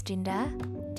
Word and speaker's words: Dinda: So, my name is Dinda, D I N D A, hope you pronounce it correctly.